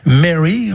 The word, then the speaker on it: Mary: Mary, on prononce comme ça, et son mari sont en goguette au bord du lac Léman.